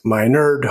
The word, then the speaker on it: Minard: My name is Dr. Matt Minard, pronounced Minard.